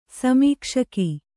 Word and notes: ಸಮೀಕ್ಷಕ: samīkṣaka

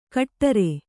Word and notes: ಕಟ್ಟರೆ: kaṭṭare

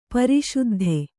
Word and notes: ಪ್ರಸಿದ್ಧಿ: prasiddhi

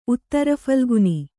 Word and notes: ಉತ್ತರಫಲ್ಗುನಿ: Kannada

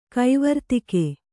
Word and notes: ಕೈವರ್ತಿಕೆ: kannada

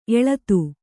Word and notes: ಎಲ್ಲಿತ್ತು: Kannada

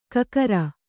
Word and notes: ಕಕರ: kakara

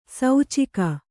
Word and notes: ಸೂಚಕ: sūcaka

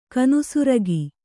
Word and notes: ಕಾನು ಸುರಗಿ: kānu suragi

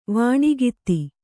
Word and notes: ವೇಣುಗೀತ: vēṇu gīna

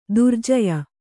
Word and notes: ದುರ್ಜೇಯ: durjēya; kannada

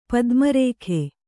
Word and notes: ಪದ್ಮರಾಗ: padma rāga

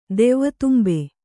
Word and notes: ದೆವ್ವದುಂಬೆ: devvadumbe

